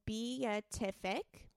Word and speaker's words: beatific: Is it emphasis? – It's beatific. Beatific.